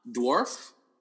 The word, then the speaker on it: dwarf: You're saying it's like it's D-O-R-F. It is not. It's dwarf. – You're overpronouncing the W like this is a bit. – You're not pronouncing the W at all like it's a bit. – It's kind of a silent W, honestly. – It's not silent. It's dwarf.